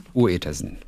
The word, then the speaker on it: Uetersen: Schreibt man Uetersen mit Ü? – Äh, UE. Uetersen.